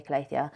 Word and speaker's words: Calathea: Some people might pronounce it differently, but I say Calathea.